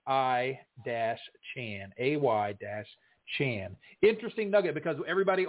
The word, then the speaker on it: Etienne: Let's move on to Kareem Hunt, Adam Thielen, Travis Etienne. I heard that Travis, you know, everybody on TV pronounces it Etienne, but actually it's pronounced I-chan. I-chan. I read on one of the posts.